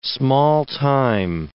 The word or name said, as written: small-time